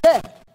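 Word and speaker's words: bad (בד): In Hebrew, it's spelled B-A-D, bad.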